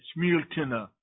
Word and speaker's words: Smiltene: It looks like it's spelled Smiltene, but they pronounce that last part of the word Smiltene.